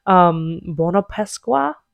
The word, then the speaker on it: buona Pasqua: I was like, lol, hey, happy Easter, buona Pasqua, which is how you say it.